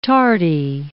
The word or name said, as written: tardy